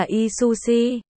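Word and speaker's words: y su si (噫吁嚱): Ôi, ôi chao, than ôi y su si, gồm 21 hay là 20 nét. Đây là một từ một thán từ. Phát âm là y su si, y u hu.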